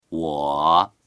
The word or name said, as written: wo3